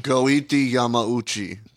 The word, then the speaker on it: Goiti Yamauchi: Against Gotti Yamamochi. – Goiti Yamauchi. – Thank you. – Oh. – Say it right. Adam. – Goethe, it's not Gotti? – No.